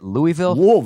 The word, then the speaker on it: Louisville: Is that how you say it? Louisville. – That's how you should say it.